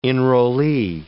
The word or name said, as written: enrollee